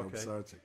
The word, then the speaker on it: Pisarcik: I guess quarterback Joe Pisarcik, am I saying it right? – Joe Pisarcik.